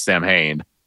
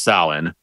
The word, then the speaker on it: Samhain: First of all, he mispronounces it as Samhain, which it should be pronounced Samhain.